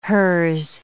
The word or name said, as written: hers